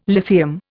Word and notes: lithium: Indian

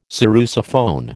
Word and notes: sarrusophone: American English

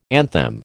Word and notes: anthem: American English

